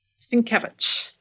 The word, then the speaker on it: Sienkiewicz: Sienkiewicz.